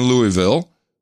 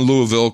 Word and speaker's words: Louisville: I currently reside in Louisville, collectively. He said, give me a pronunciation guide, Louisville, L-O-O-L-V-U-L, but I'm spending a year working for the government in the Middle East.